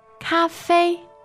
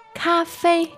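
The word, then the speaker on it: Cafe (咖啡): How do you say the word coffee in Chinese, please? – Cafe. Cafe.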